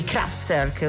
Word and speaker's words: Kraftwerk: Leggeva Ballard, ascoltava Man Machine dei Kraftwerk, oddio, sì. – Giusta la pronuncia,